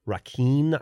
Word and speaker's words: Raquin: Thérèse, like Therese, Raquin, I think is how you say it. It's Raquin.